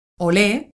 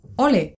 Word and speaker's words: olé: Se puede pronunciar como olé, con tilde en la E, u ole, sin tilde en la E. También es común alargar sus vocales según el momento, es decir, ole.